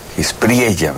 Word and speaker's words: Espriella: Bueno, además será el acto de estreno del gobernador, del nuevo presidente de Colombia, el presidente de la Espriela. Espriella, ¿verdad? Se pronuncia con la doble L, quien apenas el viernes, me parece, asumió la presidencia de Colombia.